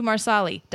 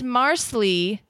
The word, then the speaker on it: Marsali: And here's more evidence that at least in the book, it's three syllables to Marsali. Diana spells it out with dashes. MARSALI. If it's Marsley, you don't pronounce the A, or it's kind of swallowed.